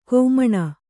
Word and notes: ಖಮಣಿ: khamaṇi

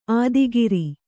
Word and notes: ಆದಿಗಿರಿ: ādigiri

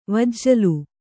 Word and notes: ವಿಜುಲಿ: vijuli

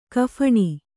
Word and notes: ಕಫಣಿ: kannada

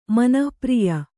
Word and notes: ಮನಃಪ್ರಿಯ: manah priya

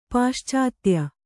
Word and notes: ಪಾಶ್ಚಾತ್ಯ: pāṣātya

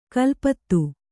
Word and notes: ಕೊಲೆಪಾತ: kole pāta

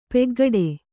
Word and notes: ಪಕ್ಕುಗುಡು: pakkuguḍu

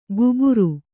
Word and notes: ಗುಬುರು: guburu

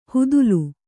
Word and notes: ಹುದುಲು: hudulu

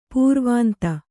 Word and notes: ಪುರವಂತ: puravanta

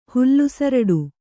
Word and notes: ಹುಲ್ಲುಸರಡು: kannada

